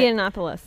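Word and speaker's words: Yiannopoulos: Giannopoulos.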